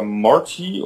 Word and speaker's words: Mort: Und der erste Charakter, der einem begegnet, ist Mort oder Morty oder wie auch immer der ausgesprochen wird. – Mort. – Mort einfach, genau.